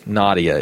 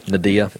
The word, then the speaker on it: Nadia: Nadia is probably, uh, what did I say? Nadia? Nadia. Yeah. Yeah, look. You put the emphasis on the wrong syllable.